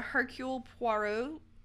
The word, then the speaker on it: Hercule Poirot: And that's actually one of the Hercule Poirot detective novels.